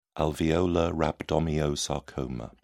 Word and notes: alveolar rhabdomyosarcoma: British English

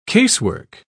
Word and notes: casework: British English

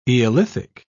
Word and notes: eolithic: British English

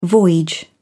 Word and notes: voyage: British English

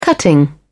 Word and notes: cutting: British English

